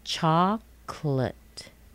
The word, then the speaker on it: chocolate: Chocolate. Chocolate. Not chocolate. Not chocolate. And I even know chocolate, four syllables. Nope, just two syllables. Chocolate. Chocolate.